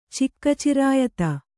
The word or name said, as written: ಚಿಕ್ಕಚಿರಾಯತ